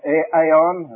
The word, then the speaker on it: aeon: This little Greek word, aeon, or aeon, as some people pronounce it, and then the adjectives of it, you get ever, ever and ever, and then unto the generation of the ages.